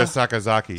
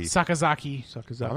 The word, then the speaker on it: Sakazaki: Riho versus the Sakuraka. – Yuka Sakazaki. – Sakazaki. – I'm actually super happy I said that right.